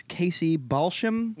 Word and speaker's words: Casey Balsham: And let's take this time now to welcome our guest to the show, Miss Casey Balsham. – Yep. – Did I say that correctly? – Absolutely.